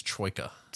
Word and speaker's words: troika: Did I say that right? Is it taroka? – No, that's the deck of fortune-telling cards from Curse of Strahd. I believe what you're looking for there is troika.